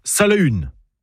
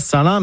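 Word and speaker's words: Salaün: Alors, déjà en breton, on prononce « Salahun ». Pas Salin, mais Salahun.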